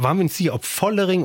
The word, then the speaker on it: Vollering: Vollering. Zwischen F und V, Vollering.